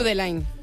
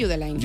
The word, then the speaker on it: Judeline: Pues entonces Judelín. No es Judeline, Judeline. Si es de Barbate, Judeline.